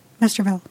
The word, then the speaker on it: Westerwelle: And this group, which, according to the Express, has been headed by German foreign minister Guido I'm not going to pronounce this correctly. Vesterville, W-E-S-T-E-R-W-E-L-L-E? – Vesterville. – Vesterville? Okay.